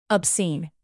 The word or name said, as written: obscene